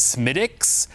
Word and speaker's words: Smithwick's: It's spelled Smithwick's, but they pronounce it Smittick's.